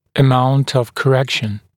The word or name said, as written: amount of correction